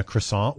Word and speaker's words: croissant: This is a French food that is spelled C-R-O-I-S-S-A-N-T.